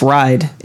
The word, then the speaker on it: Reid: R-E-I-D. Yeah. That's ride. If you're pronouncing it again to the German pronunciation, E-I, you pronounce I.